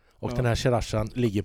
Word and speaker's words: Sriracha: Sriracha.